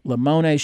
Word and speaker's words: Limone: Just Google Lamone Shoes. It's spelled L-I-M-O-N-E. It's Italian.